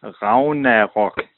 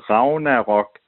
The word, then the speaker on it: Ragnarok: So the Viking way of saying it would be Ragnarok. Okay? No G, no hard G. It's more like a V. But Ragnarok is the way we usually would pronounce it here.